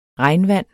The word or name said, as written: regnvand